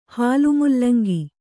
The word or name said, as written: ಹಾಲುಮೂಲಂಗಿ